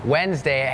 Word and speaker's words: Wednesday: โล่งออกเหรอลูกโล่งใช่ไหมเห็นแล้วถอนหายใจเฮือกว่าเริ่มเลยก่อนเริ่มรายการพี่โบพูดถึงคำว่าอะไรน้องๆจำได้ไหมคะ Silent letters ใช่ไหมคะนั่นก็คือตัวอักษรที่ไม่ออกเสียงแต่อย่างคำนี้ค่ะเป็นคำหนึ่งที่มี silent letters Wednesday has that D W E D it's not wed Wednesday Wednesday D is silent